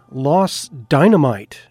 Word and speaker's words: Dynamite: That last one, Los Dynamite, and that is the proper pronunciation, because dynamite is actually not how you would say it in Spanish.